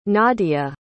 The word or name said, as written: nadya